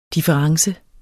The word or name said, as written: difference